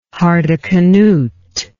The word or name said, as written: hardecanute